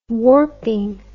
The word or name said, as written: warping